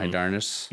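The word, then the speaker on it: Hydarnes: I do not know how to pronounce that. Hydarnius. Hydarnius. Mm-hmm. Hydarnius. – Yep. – Yeah, that's definitely it. Nailed it.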